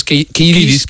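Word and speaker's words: Kiedis: Y ¿cómo pronuncia? Es Kidis.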